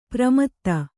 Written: ಪ್ರಮದೆ

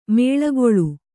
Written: ಮುಳ್ಳುಗಳ್ಳಿ

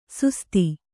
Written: ಸುಸ್ತಿ

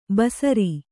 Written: ಬಸರಿ

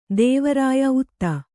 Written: ದೇವರಾಯ ಉತ್ತ

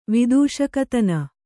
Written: ವಿದೂಷಕತನ

